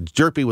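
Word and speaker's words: Hjerpe: It's a Jerpy with a silent H. at the beginning.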